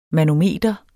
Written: manometer

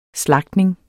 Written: SLAGTNINGERNES

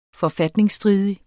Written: forfatningsstridig

